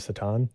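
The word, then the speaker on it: Šatan: Miroslav Satan.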